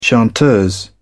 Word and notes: chanteuse: British English

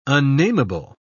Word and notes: unnameable: British English

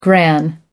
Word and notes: gran: American English

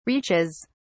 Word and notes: reaches: British English

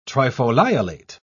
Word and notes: trifoliolate: British English

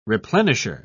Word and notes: replenisher: British English

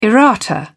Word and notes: errata: British English

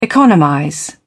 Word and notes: economize: British English

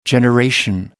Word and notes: generation: American English

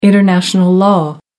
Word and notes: international law: American English

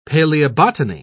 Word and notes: paleobotany: British English

